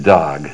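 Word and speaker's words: Doeg: Now, some of you might be familiar with the name, and I'm going to pronounce it Dog.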